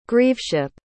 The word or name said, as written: grieveship